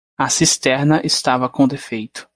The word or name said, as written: cisterna